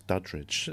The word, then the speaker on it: Duddridge: UK, Paul Duddridge. I don't know why I added a sort of syllable into his name there. It's Duddridge. but he was basically the agent for some of your favorite comedians, biggest comedians in the UK, Michael McIntyre, Rob Brydon, loads.